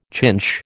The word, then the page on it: chinch: American English